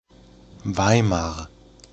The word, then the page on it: Weimar: German